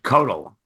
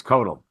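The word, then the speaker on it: Koeltl: By the way, it's Judge Codel. It's hard to pronounce, but it's Codel.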